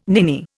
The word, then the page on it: ninny: Indian